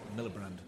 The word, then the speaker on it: Miliband: I pronounced his name wrong. What did I say? What did I say? – Miliband. – I put the R in?